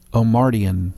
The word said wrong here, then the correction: Omartian: Omardian. Omartian. O M A R T I A N opposed to a Martian. That is not correct.